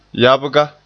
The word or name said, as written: yabga